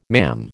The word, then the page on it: mam: American English